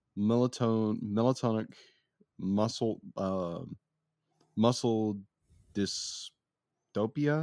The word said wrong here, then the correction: dystrophy: I believe it's pronounced. – Dystrophy.